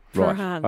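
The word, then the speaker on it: Prahran: Paran.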